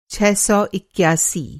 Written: छह सौ इक्यासी

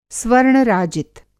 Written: स्वर्ण-राजित

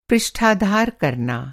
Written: पृष्ठाधार

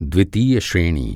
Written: श्रेणी

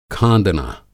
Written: खाँदना

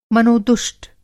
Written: मनोदुष्ट

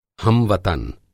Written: हम-वतन

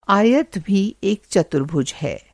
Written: चतुर्भुज

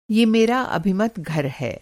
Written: अभिमत

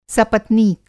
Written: सपत्नीक

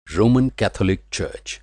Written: चर्च

